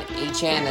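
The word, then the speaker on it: Achane: Devon Achan, as he wants to be pronounced by.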